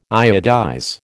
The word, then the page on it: iodize: American English